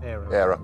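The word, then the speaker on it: Éire: I'm never sure how you pronounce E-I-R-E. Is it ERA? – ERA.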